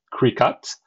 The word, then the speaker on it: Cricut: Now, this is spelled C-R-I-C-U-T, like cry, cut, but somehow spoken like Cricut.